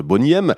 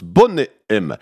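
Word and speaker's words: Boney M: Alors vous connaissez certainement le groupe des années 80-90, Bonnie M. Bon, elle prononce Bonnet M.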